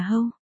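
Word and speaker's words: Hou (后): ký tự đầu tiên nên được đọc là Hou.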